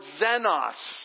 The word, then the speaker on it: xenos: The second love is the word xenos. Let's say that together. Ready? 1, 2, 3. Xenos. Now it's zen, but it's actually spelled with the letter that we use for X.